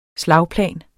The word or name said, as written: Slagplan